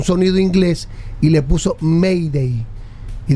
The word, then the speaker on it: Mayday: Que cuando los franceses tenían una emergencia utilizaban la palabra Mayday, que significa Mayday, que significa ayúdame, que se escribe M-Aider. Mayday se pronuncia, Mayday.